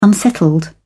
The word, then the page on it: unsettled: British English